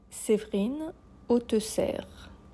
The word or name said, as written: Séverine Autesserre